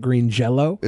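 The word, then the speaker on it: Green Jellÿ: They did the way it was spelt, but they still pronounced it Green Jello.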